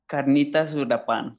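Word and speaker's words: Carnitas Uruapan: One of my favorite places to grab a bite in Pilsen is Carnitas Europan. Am I saying that right? I never feel like I get the second word quite right. – Carnitas Europan. – Europan. – Close. That's close. That works.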